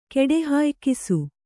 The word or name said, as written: ಕೆಡೆಹಾಯ್ಕಿಸು